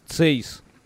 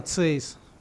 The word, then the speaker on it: Цейс: этих в, в этот э, в лейку ставят еще эти ли, стекло это Цейзеровская или... Цейс. Да, Цейс.